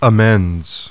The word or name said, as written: AMENDS